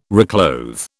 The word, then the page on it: reclothe: British English